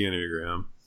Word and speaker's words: Enneagram: I know nothing about the Enneagram. Is that how you say it? Like, I literally don't know. – Yes, that's how you pronounce it.